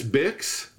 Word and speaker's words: Bics: It's spelled B-I-C-S. Bix.